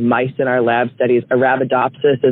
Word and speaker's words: Arabidopsis: The plant that they sent to space is pronounced Arabidopsis.